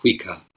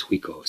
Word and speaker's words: Huiko: And this title, receiving the marrow by bowing, is a reference to our second Chinese ancestor, Huiko, which I generally had heard the name spelled with an E at the end and pronounced Huika, but Okamura uses Huiko, so that's what I'm using.